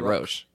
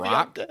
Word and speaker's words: Roch: So it's spelled R-O-C-H. How would you pronounce that? I said St. Roche. You know, like, it was like, oh, yeah, that makes Roche. – Sense. St. Roche. – Rock. – Like, it's rock. Rock.